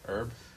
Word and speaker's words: herb: Herb. – I'm just pointing out why I got confused. Who? The H is silent.